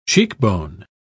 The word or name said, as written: cheekbone